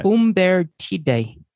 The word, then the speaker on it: Umbertide: How do you say that? – Umbertide. – Umbertide. – Umbertide. – Well, I would say Umbertide.